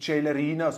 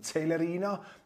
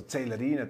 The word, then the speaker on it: Celerina: Achtung, Herr Köppel, bitte richtig aussprechen. Nicht Celerina, sondern Celerina. Das ist korrekt, darauf hinzuweisen. Da bin ich etwas von den vielen Italienern inspiriert, die sich hier den Aufenthalt genießen, ebenso wie ich. Also Celerina, das ist der richtige Aussprachemodus.